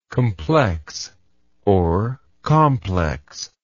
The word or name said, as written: complexness